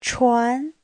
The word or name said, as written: chuan2